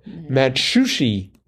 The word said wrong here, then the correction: Matsuhisa: Matsushia. Mm-hmm. No, that can't be right. Matsu, Matsuhisha. No. I'm going to try one more time and then give up. Celebrity chef Matsuhisa or his restaurant chain.